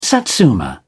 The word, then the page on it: satsuma: British English